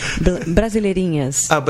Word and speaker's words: Brasileirinhas: Okay, so Brasileirinhas, is that how, am I saying it right? Brasileirinhas? – Almost. Brasileirinhas.